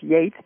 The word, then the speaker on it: Geats: The Geats, sometimes pronounced Yates, but nobody really knows.